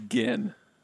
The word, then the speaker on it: Ginn: How do you say your name? – It's Ginn.